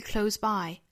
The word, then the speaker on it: close: 首先呢，我们要注意一下这个 close 这个单词，它在做动词和名词的时候呢，单词尾部应该是读浊音的，读成 close close，在做形容词和副词的时候，它的尾部应该是一个清音，也就是读成 close close，这里面呢应该是 close 读一个清音的。 It's usually close by close by close by。